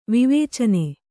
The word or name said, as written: ವಿವೇಚನೆ